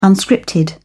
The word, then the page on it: unscripted: British English